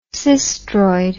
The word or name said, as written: sistroid